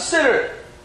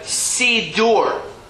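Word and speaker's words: siddur: Oh, it's a sitter. It's really a sidur. That's the proper pronunciation of that book on this table, is a sidur.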